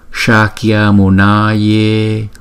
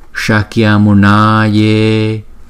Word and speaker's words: Shakyamunaya: Chỗ này chúng ta có bốn chỗ chúng ta cần chú ý nè. Chữ S chúng ta đọc là S S S. Không? Chúng ta đọc là phần palatal thì chúng ta đọc là Shakya Shakya. Không? Chữ A có kéo dài một chút. Shakyamunaya. Chữ N là N N. Và chữ A là chúng ta đọc hai nhịp.